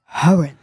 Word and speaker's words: Horan: Gleich für euch Musik aus den Charts von Mai Smith und Niall Horan. Oh, manchmal spreche ich den Namen merkwürdig aus. – Niall Horan. – Horan. – Guten Morgen. – Also kein stummes H.